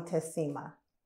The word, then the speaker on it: Tessima: when Tet is my nickname, but when she was mad at me, she would call me Tessima by my full name.